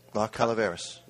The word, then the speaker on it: La Calaveras: This is La, and you can probably pronounce this better than I can. – La Calaveras. – Yep. Excellent.